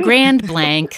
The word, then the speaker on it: Grand Blanc: Is it Grand Blanc? How do you say it? – Grand Blanc, a little bit more American. – Grand Blanc.